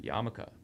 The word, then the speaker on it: Yarmulke: Yarmulke. Am I saying that right? – Yarmulke.